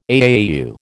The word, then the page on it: AAU: American English